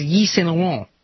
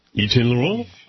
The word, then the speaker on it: Yves Saint Laurent: He, according to Forbes, it is Yves Saint Laurent. – Yves Saint Laurent? – Yves Saint Laurent.